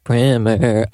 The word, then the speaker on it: primer: Yes, how it's pronounced. It's pronounced primer.